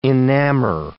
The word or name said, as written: enamor